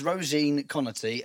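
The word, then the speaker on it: Róisín Conaty: It's, it's Roisin Conaty and Romesh Ranganathan but easy for you to say.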